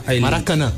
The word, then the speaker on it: Maracaná: El Macarana. ¿Cómo se dice? – Maracaná.